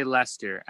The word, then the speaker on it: Leicester: That's right. Leicester City. It's not like Chester or Leicester.